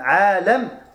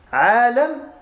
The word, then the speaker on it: عَالَم: Et celui qui va méditer sur ce monde, Al-Alam, avec un fatha sur le lam, un fatha sur le lam, Alam, le monde, Alim, ça s'écrit de la même manière avec la haraka qui change. Une kasra sur le lam, c'est un savant.